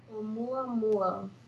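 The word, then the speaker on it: Oumuamua (ʻOumuamua): I think I was here when we to talk about the plan to look at this, but Oumuamu. – Are we I could chant too. Klaatu Vrata Niktu. – Hold on. I'm going to get the pronunciation here. Shut up. – Oumuamua. One more time? – Oumuamua.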